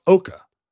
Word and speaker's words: Eochaidh: She marries the king of Ireland, whose name is spelled E-O-C-H-A-I-D-H and is pronounced Eoch. – You said Oka. – Oka. It's pronounced Oka. – That sounds right. – Yeah, Oka. – All the DH stuff goes away.